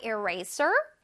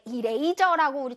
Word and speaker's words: eraser: 네, 이레이저라고 우리 친구들 많이 발음하더라고요. 이레이저, 이레이저 절대 아닙니다. 이레이저 선생님 별로 좋아하지 않아요. 자, one more time. Eraser 라고 발음해볼게요. Eraser. 잘했어요. One more time. Eraser. 자, 쓰쓰쓰 소리를 강렬하게.